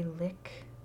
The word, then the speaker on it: Lich: I'm going to say Lick, but it's spelled like L-I-C-H.